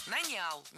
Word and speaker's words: нанял: Нанял. Неверно. Правильно, нанял. Ударение в этом слове падает на первый слог во всех формах прошедшего времени, кроме женского рода. Он нанял, оно наняло, они наняли, но она наняла.